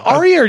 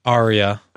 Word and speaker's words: Arya: It's spelled Arya. Okay. It's probably pronounced in our language. – In our language. – Arya. – Arya. – Arya. – In their accent you roll the stuff, R. It's Arya.